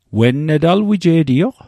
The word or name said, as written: wen net